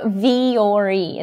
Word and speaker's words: Vuori: Am I pronouncing it correctly? I've been wearing the clothes for a long time, but I don't think I've actually pronounced it. – Yeah, so Vuori is the best way that I can say to say it.